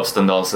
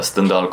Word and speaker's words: Stendhal: Han heter Stendhal. – Stendhal, inte Stendhal.